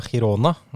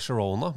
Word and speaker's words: Girona: Jeg vet det uttales cirka Girona, men finnes det en kur mot å få denne på hjernen hver gang man titter på tabelltoppen i La Liga? – Hva det du mener?